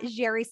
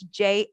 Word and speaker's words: Jheri: Well, I can be found at Jheri South, and I have a unique spelling. So it's Jheri South.